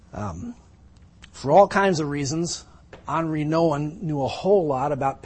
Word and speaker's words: Henri Nouwen: I used to think his name was Henry Nouwen, but you pronounce it French. It's Henri Nouwen.